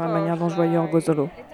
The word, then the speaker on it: Orgosolo: Allora, prima di tutto tu devi imparare a dire Orgozolo, ecco, non Orgozolo. Orgozolo.